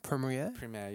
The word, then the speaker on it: Premier: But in regards to Dan Andrews, because he's our, Victorian, is it Premier? – Premier, yeah. – Premier, I can't pronounce that word.